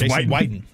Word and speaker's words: Witten: You're pronouncing it wrong this entire time. – It's Whiten.